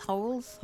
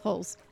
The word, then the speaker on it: Huls: H-U-L-S. – Yeah. – But it's pronounced Holes? – Holes. Mm-hmm.